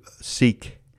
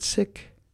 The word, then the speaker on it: Sikh: Sikh, but they pronounce it Sikh.